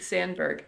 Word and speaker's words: Sandberg: What, am I saying his name right? Right? I'm saying his name right, right? David F. Sandberg? Right? – It is, yes. David Sandberg.